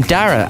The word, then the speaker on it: Dara: Well, it's a unisex name, much like the name Dara, and specifically spelt my way.